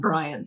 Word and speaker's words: Brion: It's uh, B R I O N. I've heard it a couple different ways and settled on Brian.